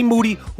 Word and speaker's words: Moody: But first, me and G Moody, whose last name rhymes with duty, are going to do our thing.